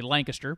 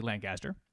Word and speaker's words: Lancaster: Here in the South, we say Lancaster, but I think it's properly, phonetically, Lancaster.